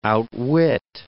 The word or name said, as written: outwit